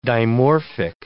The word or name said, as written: dimorphic